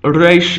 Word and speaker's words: resh: Resh is pronounced as it modernly is in Arabic and in Spanish. The Spanish and Arabic R is a rolled R at the front of the mouth.